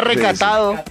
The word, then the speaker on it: recatado: A ver, yo le doy papaya. Retacado. Lo dije bien. No doy papaya. Lo dije bien. – No, era recatado.